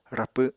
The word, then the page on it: rapw7: Apatani